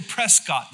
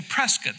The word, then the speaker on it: Prescott: They don't say Prescott there. They say Prescott. That's how you say it.